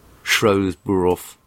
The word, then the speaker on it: Shrewsbury: I wonder if they argue about how to pronounce the name of the town. – Shrewsbury. Yes. – The son of Mary Jean, nay Mackenzie, and Vincent.